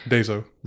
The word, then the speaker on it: Daiso: Daiso. The Daiso is how you say it.